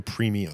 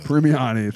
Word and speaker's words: Premiani: They were created by Arnold Drake, Bob Haney, and Bruno Premiani. – How to say that again? – Bruno Premiani. – Premiani, thank you.